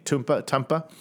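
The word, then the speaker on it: Twmpa: So this gentleman, based out of Wales, started a company called Tumpa Cycles. Now, this is spelled T-W-M-P-A. I looked up the pronunciation, and yes, that's how you say it, I think. Tumpa.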